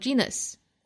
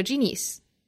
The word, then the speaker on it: genius: 比如说你这个词读成 genus 或者 genius，单词本身就读错了，肯定就不可能有那个味儿了。